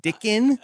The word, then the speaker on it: Dickon: Is it pronounced Dickin? – Give me a second here. Yeah. I think you're right.